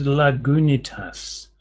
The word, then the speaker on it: Lagunitas: I've heard it pronounced Lagunitas.